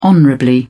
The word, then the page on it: honorably: British English